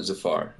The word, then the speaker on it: Zafar: Okay, am I pronouncing your name correctly? – Zafar. – Zafar. I'm sorry, like Jafar. From Aladdin. – Yeah, it rhymes, but not related.